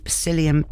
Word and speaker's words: psyllium: Psyllium. P begins with a P.